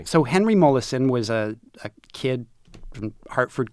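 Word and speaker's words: Molaison: Mollison. – Mollison, the most famous, possibly most studied scientific subject.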